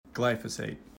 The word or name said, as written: glyphosate